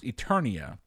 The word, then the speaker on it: Eternia: Eterna? I'm going to go with Eterna. – I will give you the points. It's pronounced Eternia, but yes, it is.